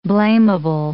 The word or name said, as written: blamable